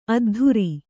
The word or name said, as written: ಅದ್ಧೂರಿ